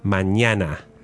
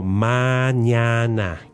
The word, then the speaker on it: mañana: So that ñe, which we call it, the N with a squiggly on top, is pronounced ña. – Ña. – Ña. – Okay. So mañana. – Yes. Mañana. Imagine a baby going like ña.